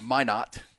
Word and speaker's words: Minot: Hey, E, it's pronounced Minot, North Dakota.